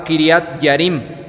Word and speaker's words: Kiriat Jearim: Anteriormente esta misma ciudad se llamaba Kiriat Yarim, se escribe Kiriat Jearim.